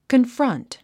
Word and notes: confront: kənfrʌ́nt; コンフロント

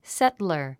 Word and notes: settler: sétlər; セトラァ

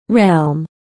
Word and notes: realm: rélm; レァーム

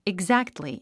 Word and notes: exactly: igzǽktli; イグザクトリィ

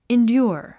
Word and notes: endure: indjúər; インデュア

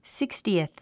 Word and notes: sixtieth: síkstiiθ; スィックスティース

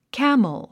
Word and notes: camel: kǽml; キャムル

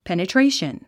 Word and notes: penetration: pènətréiʃən; ペネトレイション

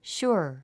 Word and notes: sure: ʃúə r; シュァ

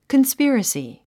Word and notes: conspiracy: kənspírəsi; コンスピラスィ

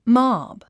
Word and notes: mob: mάb; モォブ

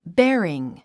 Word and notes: bearing: béəriŋ; ベアリン

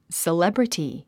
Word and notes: celebrity: səlébrəti; セレブリティ

